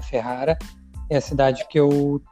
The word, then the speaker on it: Ferrara: É um fotógrafo gaúcho que está morando em Ferrara, pronunciei certo? Isso aí.